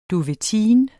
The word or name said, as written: duvetine